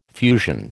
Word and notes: fusion: American English

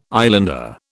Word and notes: islander: British English

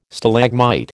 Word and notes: Stalagmite: American English

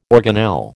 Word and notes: organelle: American English

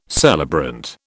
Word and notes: celebrant: British English

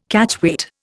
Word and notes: Catchweight: Indian

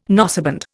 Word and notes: noseband: Indian

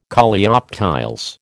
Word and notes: Coleoptiles: American English

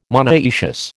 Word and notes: monoecious: American English

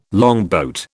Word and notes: longboat: British English

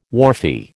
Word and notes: wharfie: American English

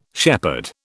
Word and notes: shepherd: British English

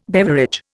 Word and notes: beverage: Indian

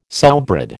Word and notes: sowbread: American English

